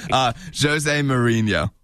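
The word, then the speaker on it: José Mourinho: No, it's Jose Mourinho. Did I say that right? – Oh. – I probably butchered that name. – Jose Mourinho.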